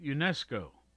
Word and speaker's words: UNESCO: 1945, founding of UNESCO.